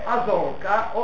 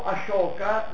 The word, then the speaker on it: Ashoka: è il re Asoka o Ashoka, qui la pronuncia è diversificata, e guardate sul punto 3 del repertorio.